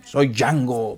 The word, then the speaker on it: Django: Django, ¿verdad? – Django. – con D. pero la D no se pronuncia, dice el actor. – soy Django, pero no se pronuncia la D.